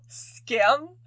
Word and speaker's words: Skam: Is the Norwegian show Skam. – She's been talking about this dang show. – It's so good. It's spelled S-K-A-M. It's the word for shame in Norwegian.